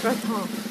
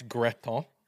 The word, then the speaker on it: gratin: Yeah, a squash gratin. That's how you say it. – All right. It sounds like you're saying crouton to me, but squash gratin.